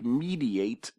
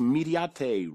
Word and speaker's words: Mediate: It looks like mediate, but it's actually pronounced mediate, Rocco Mediate, Rocco Mediate.